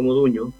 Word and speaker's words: Modugno: Yo tengo este el tambor de Domenico Modugno. Modugno se pronuncia como Ñ, GN.